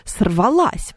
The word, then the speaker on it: сорвалась: Да-да-да, потому что звезда, как мы понимаем, сорвалась, потому что женский род, ударение на последний слог должен быть.